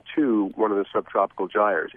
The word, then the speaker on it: Gyres: Gyres. – Five Gyres.